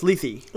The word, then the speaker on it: Lethe: So, well, this is uh, Letha, or I, actually no, it's Lethe. Lethe. It's Lethe, mm-hmm. right? – Yeah, it is.